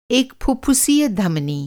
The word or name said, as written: फुफ्फुसीय